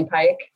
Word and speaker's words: Pyke: It's Emily Pike, P-Y-K-E.